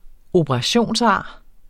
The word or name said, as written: operationsar